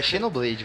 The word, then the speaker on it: Xenoblade: que se pronuncia Xenoblade. – Xenoblade meu cu, é Xenoblade, velho.